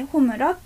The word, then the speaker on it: 炎: そうですね、火の、そう、ひいひいって上と下にひいて二つ書く漢字の炎っていう字でホムラって読むんですけど、ホムラっていうのは今年すごいヒットした、有名になった鬼滅の刃無限列車編っていう映画の主題歌でした。